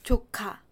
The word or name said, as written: 조카